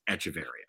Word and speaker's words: Echeverria: We looked up before the show how to pronounce it. So we believe it is Echeverria.